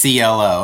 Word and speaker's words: clo: And clo is and then the letters CLO. I just want to point that out. CLO, and it's we pronounce it clo, and it's standing for a unit of clothing.